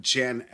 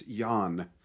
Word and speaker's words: Jan: I forgot it's not Jan Ackerman. It's Jan.